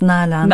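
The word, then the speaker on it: Nalan: How do you pronounce it? Nailan or Nylan? – Yeah, I pronounce it as Nylan.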